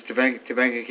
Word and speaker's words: Topanga: Topanga Canyon? Am I pronouncing that correctly? Is that right? – Yes, it's Topanga Canyon.